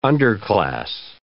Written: underclass

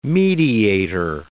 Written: mediator

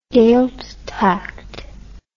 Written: gaidhealtachd

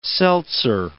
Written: seltzer

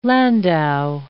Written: landau

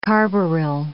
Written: carbaryl

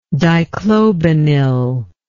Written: dichlobenil

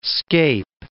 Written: scape

